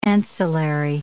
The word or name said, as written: ancillary